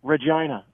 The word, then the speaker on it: Regina: What's it rhyme with? – Regina. – Vagina? – Yeah, yeah. Okay. – There's a town in Canada that's called Regina, right? R-E-G-I-N-A.